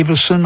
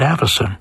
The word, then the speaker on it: Avison: Some say Avison. I understand that's the London pronunciation, but he was born in Northern England, where they would have said Avison.